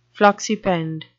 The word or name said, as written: floccipend